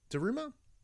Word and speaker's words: Daruma: So this is a Daruma. Daruma? That's how you pronounce it.